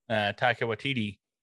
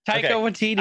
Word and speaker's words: Taika Waititi: But we see this shirt on a gentleman that is very similar to what Taika Waititi. I think I said it right, finally. – I think you did. – Did I do it? – Taika Waititi.